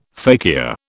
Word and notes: fakir: British English

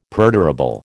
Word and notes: perdurable: American English

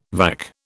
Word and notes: vac: British English

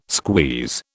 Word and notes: squeeze: British English

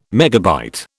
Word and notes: MB: British English

